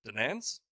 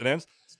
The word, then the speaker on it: Donetsk: What we wanted to do was just weaken the Ukrainian military enough and isolate them so that we can go and liberate Donetsk. Am I saying it right? – Mm-hmm. – Donetsk.